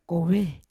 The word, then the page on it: kove: Yawa